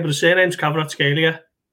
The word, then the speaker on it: Kvaratskhelia: But Kvaratskhelia.